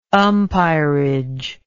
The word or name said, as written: umpirage